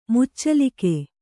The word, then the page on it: ಮುಚ್ಚಲಿಕೆ: muccalike